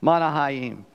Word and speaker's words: Mahanaim: So he called the name of that place Mahanaim. I spent all week trying to remember to say that name right. Mahanaim is how you say it.